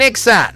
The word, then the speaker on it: Exa: Se viene el Fabián Fest en Exa 99.7. – Estás escuchando Media Naranja, Media Naranja por Exa 99.7. No se dicen éxitos, se dice Exa.